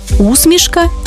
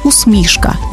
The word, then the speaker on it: усмішка: До речі, тут подвійний наголос – усмішка і усмішка, як у Василя Симоненка.